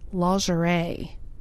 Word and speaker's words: lingerie: I say like mow the lawn. Lawn. And then like Al Jarreau, but it's not Jarreau, it's Jure. Lingerie.